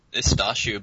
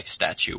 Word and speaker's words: Stachu: How's your name pronounced? – It's Stachu, but I gave up on people pronouncing it right, so just, like, Stachu works.